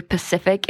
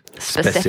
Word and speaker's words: specific: Does anyone else out there say Pacific instead of specific, specific. – or say Taisumi instead of tsunami? – That's just you. That's just me. I say it like it's typed.